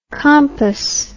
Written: compos

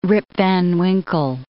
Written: Rip van Winkle

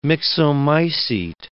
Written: myxomycete

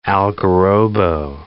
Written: algarrobo

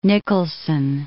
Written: Nicolson

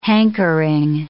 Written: hankering

